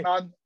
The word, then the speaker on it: Anand: Anand Muralidaran, and I based out of Silicon Valley.